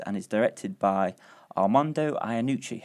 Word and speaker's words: Iannucci: Have I said that right? – Yes, you have. Although I've been to, we've done the premiere in one of the premieres in Toronto, and they have a different way of pronouncing it. They're like Iannucci. I say Iannucci, but I mean each to their own.